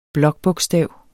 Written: blokbogstav

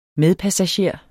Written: medpassager

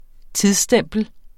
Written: tidsstempel

